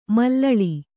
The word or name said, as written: ಮಲ್ಲಲಿ